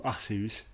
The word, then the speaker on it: Arceus: C'est Pokémon Legends Arceus, ou Arceus pour la vraie prononciation.